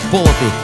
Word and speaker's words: полотый: А я подхожу к главному и говорю: послушай, если суффиксы неопределенной формы О, ну, имеют на себе ударение, то в причастиях, брат, оно перейдет на один слог вперед. В смысле? Ответил он. Я ему объясняю: полоть полотый, колоть колотый, согнуть согнутый, завернуть завернутый. Так и разошлись, не подравшись.